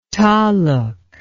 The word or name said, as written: taluk